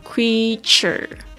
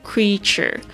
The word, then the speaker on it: creature: 我们这边呢特别来记忆几个单词，第一个是 creature，creature，c r e a t u r e，特别注意一下 e a 这个这边的发音发长音的这个 e。 creature creature 动物、生物、人，或者是指创造物。